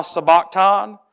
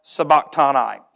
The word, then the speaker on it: sabachthani: And there are two ways you can pronounce this, the American version, the non-American version. Either one is not incorrect. You can say it, Eli, Eli, lama, sabachthan. Or you can say it, Eli, Eli, lama, sabachthani.